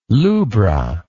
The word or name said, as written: lubra